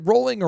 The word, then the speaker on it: Rowling: Is it Rowling or Rowling? – It is Rowling.